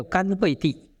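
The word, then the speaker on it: 乾慧地: 那么大品钵经发趣品有一个没有名字的菩萨实地，还有甘慧地是供我们三圣的实地，这个要念作甘，不要念作浅。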